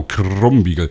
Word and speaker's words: Krumbiegel: Habe ich das richtig ausgesprochen? – Ich glaube, ihr Franken, ihr habt manchmal ein bisschen mit K und G ein Problem. – Also Krumm. – Genau, Krumbiegel. Das wird dann, ihr Franken rollt das R, das kann ich nicht ganz so gut.